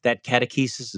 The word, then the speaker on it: catechesis: But this the Baylor professor you just mentioned told you, is, you know, that catechesis, is that how you pronounce the word? – Yeah.